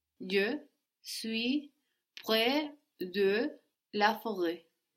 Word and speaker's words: forêt: Good, but would you pronounce that last T? Forêt. Forêt, very good.